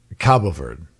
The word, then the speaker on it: Cabo Verde: I'm seeing, by the way, it's pronounced both ways. It's pronounced Cape Verde or Cape Verde, but the Portuguese pronunciation is Cabo Verde.